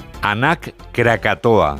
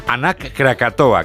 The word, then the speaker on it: Anak Krakatoa: Nos vamos a Indonesia, donde la erupción del volcán, vamos a ver si lo decimos bien, Anak Krakatoa. – ¿Lo he dicho bien? Sí. Sí, parece euskera, pero es indonesio. Sí. – Vale, perfecto, bye. Esker casco. Anak Krakatoa,